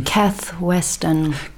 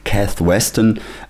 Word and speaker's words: Kath Weston: Kath Weston. – Kath Weston,